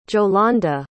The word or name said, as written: jolanda